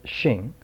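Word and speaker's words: xing (性): Richards points out here that the Chinese term for nature, pronounced xing, what is xing for Mencius?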